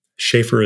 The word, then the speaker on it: Sheaffer: Sheaffer is spelled S H E A F F E R.